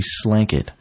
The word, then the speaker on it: Slanket: It's called a Slanket. It's spelled like blanket, but with an S instead of a B.